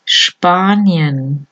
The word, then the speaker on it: Spanien: La SP al principio de la palabra o en palabras compuestas se pronuncia como SHP, o sea la SH en inglés, sh, juntos, sprechen, Spanien, Spielen, Speisesaal.